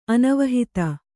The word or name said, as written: ಅನವಹಿತ